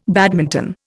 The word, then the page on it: badminton: Indian